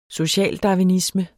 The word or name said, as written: socialdarwinisme